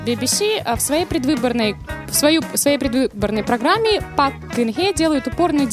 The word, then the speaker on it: Пак Кын Хэ: Пак Кын Хэ. Вот так, больше я не буду это произносить. – Хэл? – Хэ, просто как.